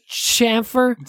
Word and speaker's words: chamfer: Chamfer? – C-H.